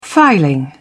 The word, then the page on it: filing: British English